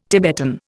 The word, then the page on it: Tibetan: Indian